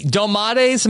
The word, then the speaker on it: domates: prepare 60 domates. Am I saying that right? – Mm-hmm. – Okay.